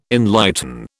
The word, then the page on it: enlighten: British English